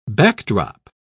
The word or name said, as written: backdrop